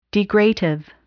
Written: degradative

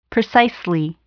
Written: precisely

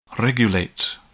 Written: regulate

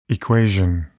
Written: equation

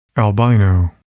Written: albino

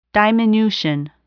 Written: diminution

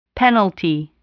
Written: penalty

